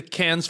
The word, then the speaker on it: Cannes: I believe it's the Cannes Film Festival. – It's not the Cannes. First of all, it's definitely not Cannes. It's French and the S is silent, for sure.